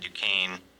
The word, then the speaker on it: Duquesne: It's pronounced Duquesne. Continue. – You must have some sort of feed. I'm saying it correctly. It said Duquesne's. – Right. Yeah, the phone adds an S.